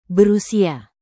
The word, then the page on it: berusia: indonesian